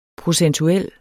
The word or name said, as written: procentuel